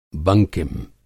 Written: बंकिम

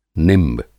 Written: निंब

